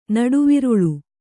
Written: ನಡುವಿರುಳು

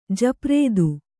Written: ಜಪ್ರೇದು

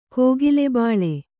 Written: ಕೋಗಿಲೆಬಾಳೆ

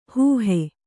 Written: ಹೋಹು

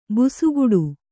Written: ಭುಸುಗುಡು

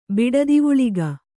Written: ಬಿಡದಿವುಳಿಗ

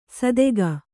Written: ಸದೆಗ

